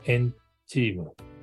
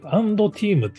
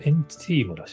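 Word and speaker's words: &TEAM: で、その視点で見ると実はこのトップ 5 に入っている 3 位もエンチーム、ンティームなのかな。アンドティームって読んだんですけど、これエンチームらしいですね。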